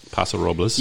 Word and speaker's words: Paso Robles: But in wine country, if you go to Paso Robles, I think that's how you say it. – Paso Robles.